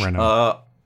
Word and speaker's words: Renault: Renault. It's Mercedes. Renault. Okay. The T is silent or the L is silent? I didn't. – It's both silent. Renault.